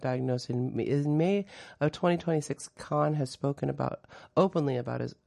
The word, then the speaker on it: Kahan: Noah Kahan, it's pronounced Noah Kahan, not Kahan.